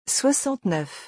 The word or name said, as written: 69